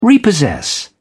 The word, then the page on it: repossess: British English